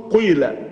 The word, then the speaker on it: قيل: الإشمام أن يهم القارئ بضم شفتيه كأنه يريد النطق بالضم، قيل ثم يكسر. كما قيل لا يكسر قيل كأنه يهم بالضمة ولكنه لا ينطق الضمة ينطق سر.